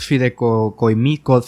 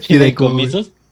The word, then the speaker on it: fideicomisos: Algo que, creo que no comentamos es el por qué iban a quitar estos fideicomisos fideicomismo, ¿cómo se dice? Fideicomisos.